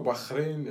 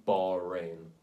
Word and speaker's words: Bahrain: I don't mind that one as much purely because, like, they do Bahrain, which is, like, horrendously wrong, but it's, like, the English way of pronouncing it, I guess. But then they'll try and do, but the locals say Bahrain, like, it's... When you're trying to, like, say it properly and then still say it wrong, that's when it's annoying.